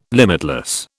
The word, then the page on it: limitless: British English